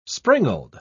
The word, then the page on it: springald: British English